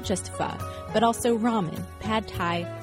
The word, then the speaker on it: pho: case you've been wondering how to pronounce that Vietnamese soup everyone's always going on about, it's officially pho, not pho.